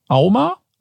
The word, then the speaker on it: AUMA: Und die Auflösung ist, im Jahr 2022 wurden laut AUMA, A-U-M-A, richtig ausgesprochen? – Ja, wurden richtig.